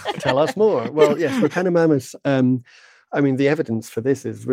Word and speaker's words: Repenomamus: Repenomamus? – Yeah, Repenomamus, yeah.